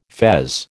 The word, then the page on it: fez: American English